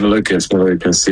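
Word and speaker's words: Malukas: Obviously, you've got Joseph Newgarten and David Malakous, I think we say his name is. – We have Malakous, yeah.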